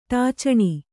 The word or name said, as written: ಟಾಚಣಿ